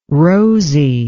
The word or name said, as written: rosie